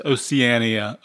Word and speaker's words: Oceania: Yes, I asked, think of a well-known seven-letter geographical name in a single word that has just two consonants, and yet it's pronounced in five syllables. And the answer is Oceania, O-C-E-A-N-I-A.